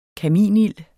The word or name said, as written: kaminild